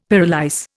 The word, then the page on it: pyrolyse: Indian